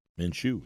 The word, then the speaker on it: Minshew: If I throw Minshew, am I pronouncing that right? – Minshew.